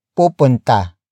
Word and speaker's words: pupunta: Word for word it's, ilan, silang, pupunta, sa, protesta.